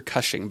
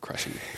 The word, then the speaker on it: Cushing: But Peter Cushing, by the way, not Crushing. Peter Cushing. – I thought it was Cushing. – Cushing? Peter Cushing? Well, that's about pronunciation. But there is no R in it. So that's the important part.